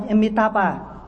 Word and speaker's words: Amitabha: Cái chữ đó đó người Trung Quốc không đọc là Nam Mô A Di Đà Phật, mà người ta đọc bằng cái tiếng phổ thông á là Nam Mô Amitabha. Amitabha.